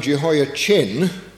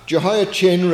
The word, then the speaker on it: Jehoiachin: Then later on, in the reign of his son Jehoiachin, I pronounce it like that so that we distinguish it.